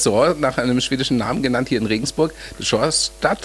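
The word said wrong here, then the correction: Storstad: spricht man? Spricht Sturstadt. Ne? – Sturstadt.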